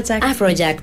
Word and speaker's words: Afrojack: Afrojack, perfecto.